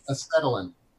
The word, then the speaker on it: acetylene: Acetylene.